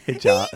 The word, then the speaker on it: hijab: Hijab, sorry. I say it wrong. Hijab.